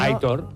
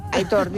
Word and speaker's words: Aitor: Es un oyente que dice, por favor, que Aitor se pronuncia como se lee. – Sí, ¿no? – Aitor. – Aitor. La acentuación es en la O.